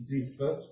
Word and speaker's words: Ziph: Questo deserto poteva corrispondere alla foresta di Cheret, di cui si legge in prima Samuele 22:5, o a quello di Zephir, al deserto di Ziph, scusate, di prima Samuele 23:15.